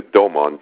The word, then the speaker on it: Doman: This is Dr. Alexander Doman, D-O-M-A-N,